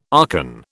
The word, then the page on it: archon: British English